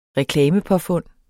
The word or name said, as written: reklamepåfund